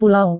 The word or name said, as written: pulau